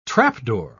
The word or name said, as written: trapdoor